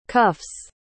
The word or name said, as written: cuff's